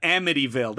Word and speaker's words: Amityville: It's the Amityville horror box set that Amityville.